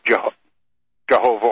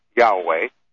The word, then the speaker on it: Yahweh: These descendants of the Khazars, who truly accepted Judaism and served Jehovah, or more properly pronounced, Yahweh, are true converts.